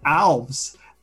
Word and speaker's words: Alves: I thought I had come up with the best way to explain how to say my name by saying it rhymes with valves.